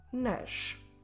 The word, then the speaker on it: nas: So what happens is that u, a, ush, ash will change into nu, na, nush, nash. How do we spell? Como se escreve nu, na, nush, nash? Nu, n-o. Na, n-a. Nush, n-o-s. Nash, n-a-s.